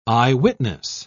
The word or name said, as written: eyewitness